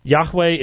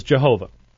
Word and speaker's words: Yahweh: Yahweh is slaughtered in English as Jehovah. Jehovah is not a possible pronunciation. That's clearly from the Germanic. It's just not what the Jews said, and so... Even at that, it has an extra syllable in it because they put the vowel pointing for Adonai into Yahweh. Yahweh is two syllables.